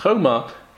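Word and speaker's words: choma (חומה): It's still pronounced choma, but it's without a vav.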